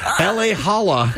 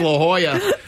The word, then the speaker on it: La Jolla: I love listening to Chet GPT mispronounce L.A. Holla. – Yeah. – At least I can pronounce La Jolla, not real person.